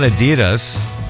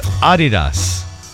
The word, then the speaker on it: Adidas: So Americans pronounce Adidas wrong. It's not Adidas. It's Adidas.